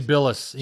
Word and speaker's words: Jay Bilas: So this was tweeted by Jay Bilas? How do you say Jay Bilas.